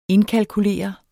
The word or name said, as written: indkalkulere